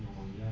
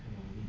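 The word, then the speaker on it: pneumonia: خودش سوریاسیس پیش تلفظ نمیشه مثل نومونیا که پنومونی نیست.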